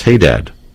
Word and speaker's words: Haddad: Now, Dearborn Police Chief Ronald Haddad, but you said I've been mispronouncing it for years. It's Haddad. – I call him Haddad.